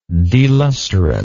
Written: delusterant

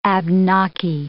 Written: Abnaki

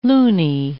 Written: looney

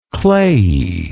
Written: clayiness

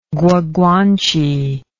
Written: guaguanche

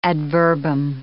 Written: ad verbum

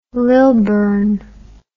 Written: lilburne

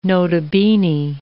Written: nota bene

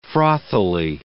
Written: frothily

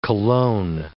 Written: cologne

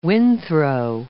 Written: windthrow

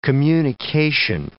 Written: communication

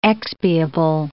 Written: expiable

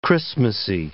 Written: Christmasy

